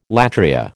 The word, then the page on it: latria: American English